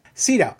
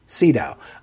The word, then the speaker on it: Sydow: Sydow. I've never heard it pronounced this way, but in both of the newscasts I heard today, it was pronounced Sydow. I've never heard it pronounced that way.